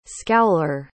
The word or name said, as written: scowler